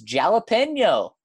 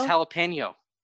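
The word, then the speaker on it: jalapeño: I would say that the Americanized pronunciation really isn't that far off from the real pronunciation. It's just it doesn't have the same accent on it. – Jalapeno. Yeah, it's the tilde, right? – Yes, over the end.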